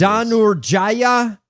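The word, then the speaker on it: Dhanurjaya: Danur Jaya.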